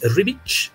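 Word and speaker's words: Ribić: nunca me había tocado escucharlo el apellido como debería decirse. Es Rivich. CH.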